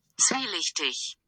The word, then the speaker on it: Zwielichtig: This is the hardest word because the way you spell it is not the way you think you would say it. Oh. It's Swi-leth-teth. Whoever has the lisp, I am so sorry. I can't say this word. But this is a way. Let me play it for you. – Zwielichtig. – Oh, wow. – Zwielichtig. – But the word is spelled Z-W-I-E-L-I-C. H-T-I-G. I know.